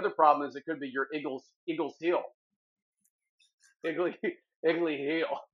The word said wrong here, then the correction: Achilles: How do you say it again? – It's Achilles. – It's the way it's spelled. It's spelled like A is Chili's.